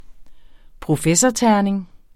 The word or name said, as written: professorterning